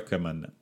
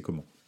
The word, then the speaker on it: Coman: mais son nom se prononce comment pour de vrai. Euh, c'est pas euh, c'est pas Coman.